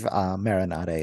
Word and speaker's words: Maren Ade: A-D-E. – Yeah, I believe Maren Ade is correct, or is the correct pronunciation.